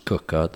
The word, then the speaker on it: Quooker: We're first of all sponsored by Cooker, spelt Quooker, pronounced Cooker, the hot water taps, which I absolutely love.